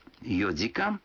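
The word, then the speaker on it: йодикан (四時): Некоторые цифры в этих случаях произносятся не так, как обычно. 4 часа – это йодикан, 7 часов – ситидикан, а 9 часов – кудикан.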